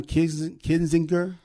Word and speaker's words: Kinzinger: And Adam Kinzinger, did I say it right? Mm-hmm. K-I-N, Kinzinger.